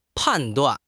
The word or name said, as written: pan4 duan4